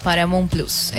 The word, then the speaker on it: Paramount Plus: ¿Lo dije bien? ¿Paramount Plus? – Paramount Plus, está muy bien.